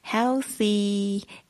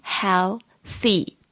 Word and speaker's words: healthy: 那这个 live 是直播的意思现场的意思但是呢当它发音成 love 就是活去生活的意思那今天要怎么样活呢就是要活得健康那 healthy healthy 就是健康的意思 healthy H-E-A-L-T-H-Y 特别注意一下这个 E-A 这边的发音是发 E 的声音 healthy th 这个声音呢是这个吐舌音然后呢就是轻轻的吐气出来 th healthy